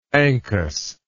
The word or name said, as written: ankus